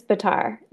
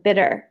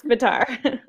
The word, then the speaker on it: Bitar: Yes, it's Bitar. The other way to pronounce it is like, it's like bitter. And it's not that. It's Bitar.